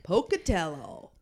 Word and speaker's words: Pocatello: Pocatello, Idaho. – Pocatello.